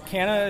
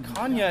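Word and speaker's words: Canha: Yeah, it's a Portuguese name, so technically in Portuguese you would pronounce it Kanya. – Sure. – I'm like third or fourth, probably fourth generation American at this point, so I don't really... It's been Canna my whole life.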